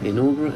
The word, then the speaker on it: inaugural: Inaugural, is that how you say it? – Yeah, inaugural.